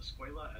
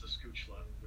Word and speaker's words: escuela: At the start of my third year for Spanish, I pronounced escuela as escuchla, which means school.